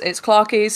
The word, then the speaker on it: Clarkeeee: So it's Clarkie, spelled with like four E's and an underscore.